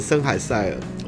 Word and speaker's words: 森海塞尔: 好，耳机哦，耳机今天还没有业配，森海尔送的啊，呃呃，怎么念啊？我都念森海尔，然后他们都念森海塞尔。